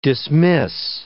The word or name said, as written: dismiss